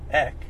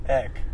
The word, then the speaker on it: Aeck: Okay, so the A is silent. – Go talk to my great-great-great-great... – So... – Great-grandfather. – Colton's last name is A-E-C-K. If you guys are wondering how to say it, and you're at home, and you're saying his name, leave out the A.